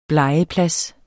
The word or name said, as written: blegeplads